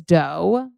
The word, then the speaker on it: Deux: So this is Dough. That's spelled D-E-U-X, okay? Like dough, like cookie dough, like dough.